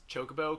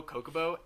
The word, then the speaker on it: Chocobo: I had a cousin that used to pronounce Chocobo, Kokobo, and I would just fucking want to strangle her so bad. – Oh my gosh. – Why would you think it's Kokobo? There's a CH.